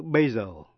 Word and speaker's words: basil: Nhưng trong chữ basil, basil, B-A-S-I-L, là một thứ húng quế, thì âm S phát âm là Z.